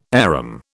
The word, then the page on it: arum: British English